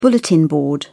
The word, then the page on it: bulletin board: British English